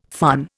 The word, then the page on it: fun: Indian